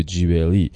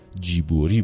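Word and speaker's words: جیبوری (ジブリ): استدیو جیبلی یا به تلفظ خود ژاپنی ها جیبوری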